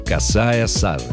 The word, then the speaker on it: сажень: Сажень указывает, что знаменитый фразеологизм – это сложившийся устойчивый оборот, и именно его нужно произносить с ударением на первом слоге. «Косая сажень».